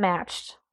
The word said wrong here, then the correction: Mashed: I realize I pronounced that wrong. Mashed, as in potatoes.